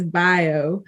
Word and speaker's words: Bayou: He was born in a place called Biomeda, and they pronounce Bayou different in this part of the state. So I pronounce it as Bio.